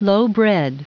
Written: lowbred

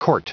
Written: court

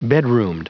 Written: bedroomed

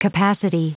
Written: capacity

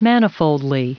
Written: manifoldly

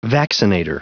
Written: vaccinator

